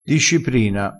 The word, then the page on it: disciprina: dialettale